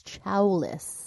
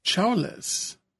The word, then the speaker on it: Chowlis: But before I do, can I just say, I received a message from Shalisa's sister, and apparently I was pronouncing her name wrong. It is Chowlis. – Chowlis. – Chow, like Chowlis.